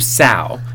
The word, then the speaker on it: sow: And he starts having sex with her really hard, but then he goes, "I'll punish you instead, you sow," which I had to look up. And then I also just had to look up again because I forgot how to pronounce it because it's spelled S O W.